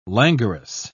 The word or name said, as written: languorous